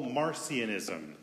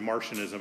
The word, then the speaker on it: Marcionism: One of the earliest heresies we had in the church history was something we call Marcionism. Some people pronounce it Martianism, but that sounds a little too science fiction for me.